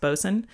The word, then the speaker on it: boatswain: A bosun, spelled B-O-A-T-S-W-A-I-N, but it's pronounced bosun.